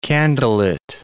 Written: candlelit

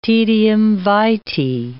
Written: taedium vitae

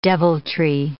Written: deviltry

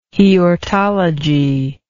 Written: heortology

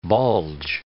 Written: bulge

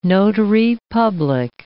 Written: notary public

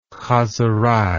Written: chazerei

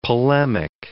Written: polemic

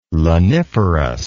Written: laniferous

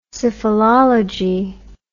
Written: syphilology

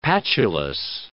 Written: patulous

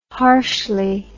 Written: harshly

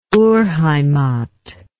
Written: urheimat

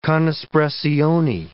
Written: con espressione

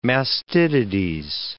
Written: mastitides

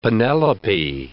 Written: Penelope

